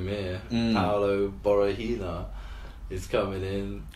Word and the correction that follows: Borrachinha: Did I say that right? – Borahina. Nah, not quite. – Borrachinha.